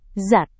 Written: zat

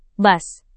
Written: bus

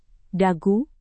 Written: dagu

